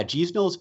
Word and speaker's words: Guignol: Yeah, Gisnol's Band. The spelling is Guignol. So if we were to pronounce it phonetically as in English way, we'd say like Guignol's Band or something like that.